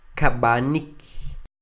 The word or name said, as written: Kab'anik